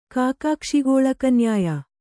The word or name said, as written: ಕಾಕಾಕ್ಷಿಗೋಳಕನ್ಯಾಯ